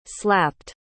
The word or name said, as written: slapped